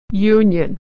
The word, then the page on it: union: /y/